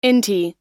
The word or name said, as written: inti